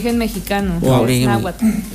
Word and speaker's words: Náhuatl: Es que es difícil de pronunciar porque es N-A-H-U-T-L. Sí, T-L, sí. Sí. – Náhuatl. Entonces, bien. Es como Tetzaclenalgo, una vaina así, pero dice tla.